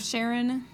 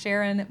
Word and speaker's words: Charon: And yes, that's how you pronounce that moon. I'm sure I'll get hate mail about that too. It's named after the guy's wife. That's why it's pronounced Charon, but it's spelled like Karen because it's a mythological person.